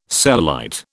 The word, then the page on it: cellulite: British English